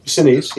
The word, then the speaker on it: Sinise: which had Gary Sinise. Is that how you say it? – Yeah. – Sinise, yeah.